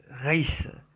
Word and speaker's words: RICE: Et d'ailleurs, en passant sur le site de ZAMS, on peut voir que vous mettez en avant le protocole RICE. RICE, selon comment on le prononce. – Rice, RICE. On va le prononcer en anglais, on va dire.